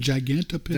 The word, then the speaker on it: Gigantopithecus: Gigantopithecus.